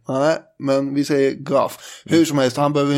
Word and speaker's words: Gough: G-O-U-G-H. – Goff. – Ju mer jag uttalar det här namnet så får jag känslan av att det kanske inte är Gogg. Det är inte fan Gogg vi pratar om här utan det kanske är Goff. – Ja, det kan nog vara en bra gissning. Det är G-O-U-G-H.